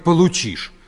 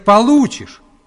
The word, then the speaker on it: получишь: Николай Владимирович, нельзя говорить «получишь». Надо говорить «получишь». Вы неправильное ударение ставите.